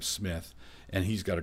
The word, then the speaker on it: Psmith: And I have to spell Smith. It's P-S-M-I-T-H, Smith. The P is silent.